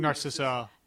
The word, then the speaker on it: Narcissa: And this is the first time you meet Narcissus. How do you say her name? – Narcissa. – Narcissa, whatever.